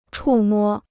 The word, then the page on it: 触摸: chù mō